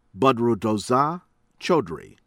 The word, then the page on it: Badruddoza Chowdhury: American English